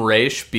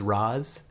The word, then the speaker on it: Ra's: I will always call him Raish because of the animated series. But I think the correct pronunciation supposedly is Roz.